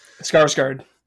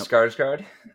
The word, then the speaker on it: Skarsgård: Skarsgård. – Yep. Skarsgård.